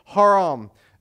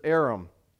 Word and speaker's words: Aram: If you were to pronounce this, it's a hard breathing sound at the beginning, which actually haram is how you pronounce it in Hebrew. But we, when they transliterate it into English, it just says Aram.